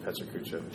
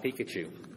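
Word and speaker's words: Pecha Kucha: Pechikucha. I was wrong. – We just watched a video on how to say it. So. – It's not Pikachu. Learn that.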